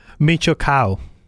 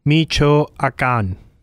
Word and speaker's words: Michoacán: M-I-C-H-O-A-C-A with the thing on top, N. So, what you got for me? – Michoacán. – What? – Michoacán. – Michoacán. – Michoacán. Yeah. – Yeah. M-I-C-H-O-A-C-A with a thing.